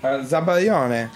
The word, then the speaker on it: Zabaglione: Zabaglione.